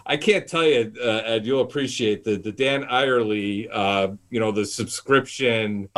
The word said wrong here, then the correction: Ariely: Oh, Dan Ariely.